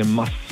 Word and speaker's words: Maß: Die Betonung ist auf dem S, also eine Maß.